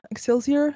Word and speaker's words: Excelsior: Yeah, I lived in Outer Mission called Excelsior is how you pronounce it.